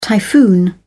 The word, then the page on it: typhoon: British English